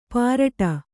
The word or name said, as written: ಪಾರಟ